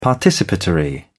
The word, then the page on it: participatory: British English